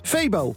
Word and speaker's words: Febo: En Il Febo is vanuit uitgesproken als Febo.